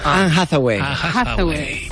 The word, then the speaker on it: Anne Hathaway: Anne Hathaway.